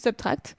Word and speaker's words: SBTRKT: Donc, pour ceux qui cherchent Subtract, c'est S-B-T-R-K-T. Voilà, ça se prononce Subtract.